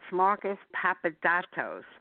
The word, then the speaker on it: Marcos Papadatos: That's Marcos Papadatos, and I'll spell it as well. Marcos Papadatos, because I had to really work on the pronunciation.